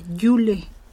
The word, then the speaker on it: Yule: Björn Blindison, Blindison dice, ah, está un poco lento, saludos nuevamente, se pronuncia coloquialmente Yule.